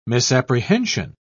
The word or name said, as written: misapprehension